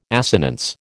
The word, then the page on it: assonance: American English